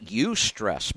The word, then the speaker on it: eustress: and it's spelled E-U-S-T-R-E-S-S, eustress.